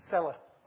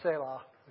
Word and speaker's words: Selah: Cella, or Selah, as it's pronounced in more better.